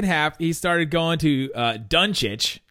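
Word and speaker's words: Dončić: All the different ways Marv Albert mispronounced Luka Doncic's name tonight. – How many were there? – Okay, in the first half, he was calling him Donk Itch.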